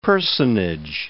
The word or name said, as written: personage